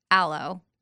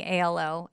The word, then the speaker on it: Alo: I'm wearing ALO, aloe, I think is how you say it, yoga pants.